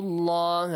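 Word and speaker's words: الله: فَاتَّقُوا اللَّهَ. Allah is pronounced with an A sound.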